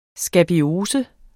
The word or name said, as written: skabiose